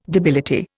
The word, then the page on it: debility: Indian